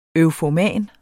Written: eufoman

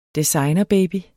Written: designerbaby

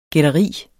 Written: gætteri

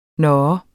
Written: nå

